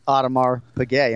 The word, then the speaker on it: Audemars Piguet: And it's not Audemars Piguet.